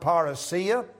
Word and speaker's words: parousia: It is the word parousia.